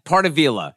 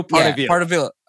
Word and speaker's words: Pardavila: Yeah, Pardavila. Yeah, I know you're sensitive about names. So yeah, so it's Joe Pardavila.